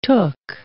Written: took

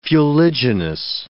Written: fuliginous

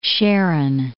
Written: Sharon, Plain of

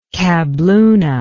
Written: kabloona

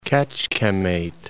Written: Kecskemet

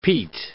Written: peat

